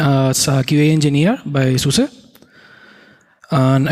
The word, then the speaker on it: SUSE: But I need to be sure that you first know how to pronounce this word, because there is a lot of confusion. It is SUSE. I would like that you repeat with me, so to be sure that you can pronounce this. SUSE.